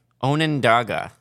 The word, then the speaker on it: Onondaga: Is that how you say it? – Onondaga. – Onabaga is how I said it, because... – Well, there's no B, it's a D.